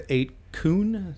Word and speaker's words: 8kun: And then moved to 8kun.